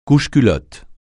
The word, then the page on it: couche-culotte: francais